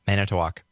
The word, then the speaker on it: Manitowoc: Yeah, Manitowoc.